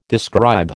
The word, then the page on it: describe: American English